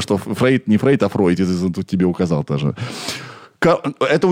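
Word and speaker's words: Freud: Собственно, Фрейд был довольно образованный в еврейском плане человек. Если я не ошибаюсь, правильно говорить Фройд.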